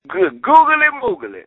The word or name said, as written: great googly moogly